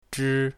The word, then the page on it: zhi1: mandarin